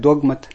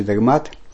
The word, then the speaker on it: догмат: У нас в церковной среде чаще говорят догмат, а в университетской среде говорят чаще догмат. По-славянски тоже говорится догмат. Если славянский текст мы читаем, там написано не догмат, а догмат, ударение поставлено. Так что здесь можно и то, и другое правильно, но я предпочитаю говорить слово догмат, хотя догмат тоже это правильно.